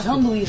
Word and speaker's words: Janduí: Obrigado ao Vanduí, é isso? Falei certo? – Janduí. – Janduí, Janduí. Tá aqui na capa com o Luan Santana, né?